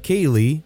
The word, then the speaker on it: Caeleigh: My wife suggested C-A-E-L-E-I-G-H, pronounced Kaylee, and yes, she spelled it out. I vetoed it. She suggested another name, R-Y-L-E-I-G-H, Riley, and again, she did spell it out.